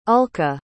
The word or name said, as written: ulka